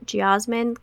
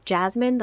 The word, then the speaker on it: geosmin: I've always pronounced it geosmin, kind of like jasmine, the flower, because the first time I heard it was from my sensory professor who was from South Africa, and she had this really beautiful accent that I loved. But now, lately, I've heard some people pronounce it more like it's written. It's written G-E-O, S-M-I-N, geo as in earth. So some people pronounce it geosmin. I say jasmin.